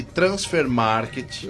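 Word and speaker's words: Transfermarkt: Vamos lá, segundo o site Transfer Market, que é o site alemão aqui.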